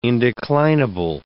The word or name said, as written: indeclinable